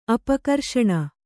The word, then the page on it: ಅಪಕರ್ಷಣೆ: apakarṣaṇe